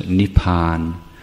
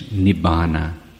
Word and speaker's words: nibbana: So what in Thai we pronounce as nipan. Then in the alternative pronunciation it's nibbana, or the Sanskrit version nirvana.